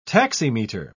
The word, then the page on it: taximeter: British English